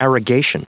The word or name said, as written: arrogation